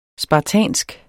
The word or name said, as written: spartansk